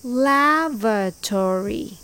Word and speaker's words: lavatory: Lavatory. It's pronounced lav-a-tory. It's very similar. The stress is on the first syllable, lav, with V sound.